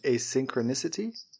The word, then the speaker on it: asynchronicity: And the asynchronosity. How do you say it? – Asynchronicity, is it, I think? – Yeah.